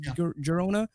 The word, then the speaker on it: Girona: I don't know how to pronounce it properly. – Girona, yeah.